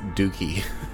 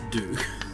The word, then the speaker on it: Duke: I believe it's pronounced Dookie. – It's not. It's Duke.